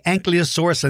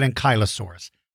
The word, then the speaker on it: Ankylosaurus: Now, you will hear me say Ankylosaurus and then I'll pronounce it Ankylosaurus. I kind of switch between the two.